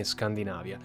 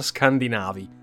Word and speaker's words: Scandinavia: e quindi possiamo includerli almeno concettualmente nel termine Scandinavia. Inoltre si pronuncia Scandinavi, non Scandinavi, come spesso si sente pronunciare. Dunque, parlare dopo questa introduzione di guerra e Scandinavia sembra quasi un eufemismo.